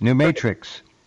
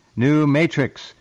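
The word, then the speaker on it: NuM8TRX: Max has another product called, I'll spell it, N-U-M, and then you look and you'll see the numeral 8, New Mate 8 TRX, New Matrix, New Matrix.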